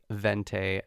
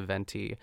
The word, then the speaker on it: venti: I wonder if like Aina says like vente at Starbucks as opposed to venti.